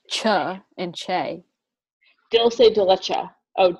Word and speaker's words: dulce de leche: Dol dulce dulce de leche.